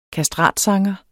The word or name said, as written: kastratsanger